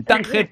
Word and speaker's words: Tánger: Yo lo de Tánger, iba a decirlo casi en inglés. In Tánger, Tetuán, Alhucemas. Lo he dicho bien, ¿no? En cualquier caso, ¿no? – Muy bien.